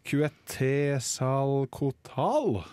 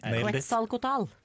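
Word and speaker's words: Quetzalcoatl: Og jeg har tatt den er bare, altså jeg, skal, jeg skal nå uttale navnet perfekt for dere. Eh, Kuetesalkotal. Eh, sånn som de sa det i gamle dager. – Kuetesalkotal.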